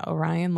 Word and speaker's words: Orion: Brennan felt braggy Orion, or is that how you say it? Yeah, Orion, like mm-hmm. the mm-hmm. constellation.